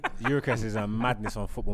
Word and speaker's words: Gyökeres: Gyökeres. Good. – I watched him at Coventry. He was good, yeah. – He was too good for that league. – Soft G. – Gyökeres, yeah.